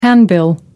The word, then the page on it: handbill: American English